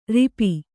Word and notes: ರೋಪ: rōpa

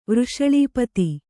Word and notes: ವೃಷಳೀಪತಿ: Kannada

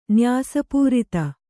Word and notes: ನ್ಯಾಸಪೂರಿತ: kannada

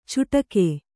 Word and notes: ಚುಟಕೆ: cuṭake

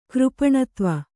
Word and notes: ಕೃಪಣತ್ವ: kannada

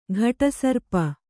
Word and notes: ಘಟಸರ್ಪ: ghaṭa sarpa

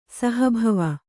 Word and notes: ಸಹಭವ: Kannada

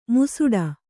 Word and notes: ಮುಸುಡ: musuḍa

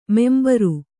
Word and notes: ಮುಂಭಾರ: mumbhāra

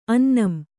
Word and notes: ಅನ್ನಂ: annam

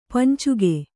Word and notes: ಪಂಚಕ: pancaka

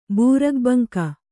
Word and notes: ಬೂರುಗಬಂಕ: Kannada

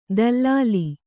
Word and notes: ದಲ್ಲಾಲಿ: dallāli

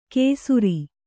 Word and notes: ಕೇಸುರಿ: kēsuri

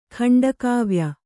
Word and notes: ಖಂಡಕಾವ್ಯ: khaṇḍa kāvya

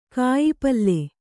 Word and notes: ಕಾಯಿಪಲ್ಲೆ: kāyipalle

